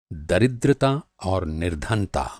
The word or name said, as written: दरिद्रता